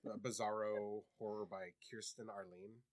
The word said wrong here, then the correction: Aline: I knew I was going to say that wrong. Mm-hmm. And I wrote it, and I looked at it, and then I still put the R in. There's no R. It's Aline.